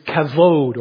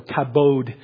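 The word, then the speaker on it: kavod (כבוד): And the Old Testament word is a word, the Hebrew word is kavod, or kabod, as it's spelled with a B, but you say the kavod.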